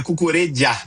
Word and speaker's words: Cucurella: Cucurella, Cucurella, como é que é o correto? Porque a gente aqui vive brigando com essa pronúncia. – Cara, é Cucurella.